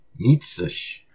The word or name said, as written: Mitsush!